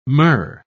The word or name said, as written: myrrh